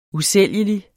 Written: usælgelig

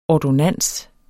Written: ordonnans

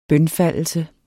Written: bønfaldelse

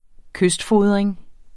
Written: kystfodring

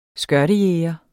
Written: skørtejæger

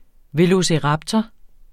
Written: velociraptor

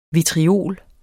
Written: vitriol

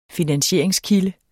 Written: finansieringskilder

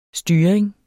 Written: styring

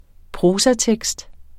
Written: prosatekst

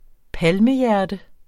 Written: palmehjerte

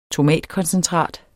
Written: tomatkoncentrat

